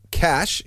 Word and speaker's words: cache: And so the cache is actually the correct pronunciation of it. – Yeah, cache as in stash.